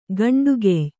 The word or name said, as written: ಗಂಡುಗೆಯ್